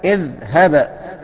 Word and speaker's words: اذهب: لا نكسر، لأن إن التالت إذا كان مكسور أو مفتوح يبقى نكسر همزة الوصل، يبقى لازم نكسرها. أيوة، اذهب، تمام؟ تنطق هكذا، اذهب. طيب حاجة تانية، كلمة زي ارجع، ننطقها ازاي؟